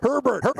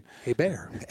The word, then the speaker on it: Hebert: Herbert. – So George Costanza explaining. Yeah. See, there's no H, but he's, yeah. you don't pronounce it. – Hey, Bear.